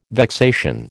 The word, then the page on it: vexation: American English